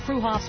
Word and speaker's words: Freehoff's: Spelled Freehoff's, pronounced Freehoff's, at 33rd and Arapaho in Boulder.